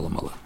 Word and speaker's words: Mala: I llavors, això per una banda, i llavors fin, llevar el coll del penyal blanc, que està també a prop de la Mala. – Mala. – A la Mala. La Mala és una part d'Arianta, pronunciada així, la Mala.